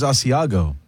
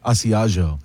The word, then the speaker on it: Asiago: Is that a soft G? I thought it was Asiago. – What did I say? – Asiago.